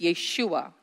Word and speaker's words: Yeshua: So in Aramaic and pronunciation, it's Yeshua. Jesus, on the other hand, doesn't have any meaning as a name, but the name Yeshua has meaning.